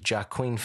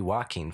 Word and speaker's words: Joaquin: She's like, Joaquin Phoenix. And I was like, she's like, I'm saying this wrong, aren't I? I said, absolutely. – Yeah. – That would be Joaquin Phoenix, but I'd be very interested to see what Joaquin Phoenix looked like.